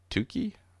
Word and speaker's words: Tukey: But then what I did was I found this, the pairing with an ANOVA is apparently a Tukey range test.